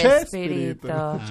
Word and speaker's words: Chespirito: Chespiriño. – Sí. El Chavo de los. – Y ahí en Argentina dice Chespirito. – ¿Cómo se dice? – Chespirito. – Chespirito.